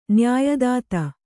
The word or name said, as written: ನ್ಯಾಯದಾತ